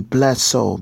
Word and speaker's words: Bless'd S.o.u.l.: But so it would be Bless'd S.o.u.l. B-L-E-S-S apostrophe D S-O-U-L.